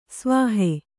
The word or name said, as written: ಸವಿಹ